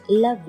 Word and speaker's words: love: And let 留下后面的爆破辅音，你可以把 love 和 you 连读成 love you。同时我们注意 love 中间的小声音的 a、上齿咬下唇 love。